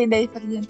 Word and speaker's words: Divergent: Oh Divergent ya bacanya? Jadi gimana? Iya kali Divergent.